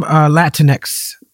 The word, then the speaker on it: Latinx: With the term Latinx, I didn't say that right, did I? – You did.